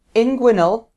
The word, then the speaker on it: inguinal: I've recently noticed the start of an inguinal hernia.